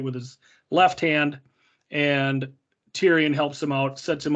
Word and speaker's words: Tyrion: where Tyrion says, that's how it's pronounced, Tyrion.